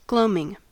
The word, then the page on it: gloaming: American English